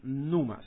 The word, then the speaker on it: pneuma: Numa, se escribe, en español se escribe P-N-E-U-M-A, con acento en la U, numa.